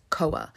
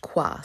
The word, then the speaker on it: Khoa: I would say his name was Koa, but it's actually pronounced Kwa. So it's spelled K-H-O-A, but it's pronounced Kwa, and he's a central figure in this story as well.